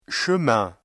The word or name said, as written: chemin